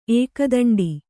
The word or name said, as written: ಏಕದಂಡಿ